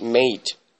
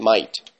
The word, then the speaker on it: mate: And it's supposed that it has to be pronounced mate, but it's pronounced might. Because in Australia, people pronounce it might. So it's like classmate. M-A-T-E. So in English, in regular English, like American English or even British English, they say mate. But in Australia, they say might.